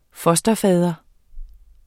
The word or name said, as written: fosterfader